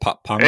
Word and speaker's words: Papango: Papango. Is that how you say it? Papango. – Yeah, at the end of the game,